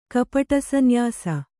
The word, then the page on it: ಕಪಟಸನ್ಯಾಸಿ: kapaṭa sanyāsi